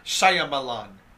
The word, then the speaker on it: Shyamalan: Shyamalan, right? – Shyamalan. – Shyamalan.